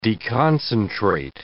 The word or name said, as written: deconcentrate